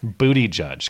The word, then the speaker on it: Buttigieg: Man, what is the gay community coming to if, like, Pete Buttigieg, I don't even know how to say his name still. – Booty Judge. Go with the sticks pronunciation.